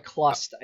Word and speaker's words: Chlust: Or, sorry, Clust, I guess it's pronounced, even though it's spelled with the CH. It's Clust.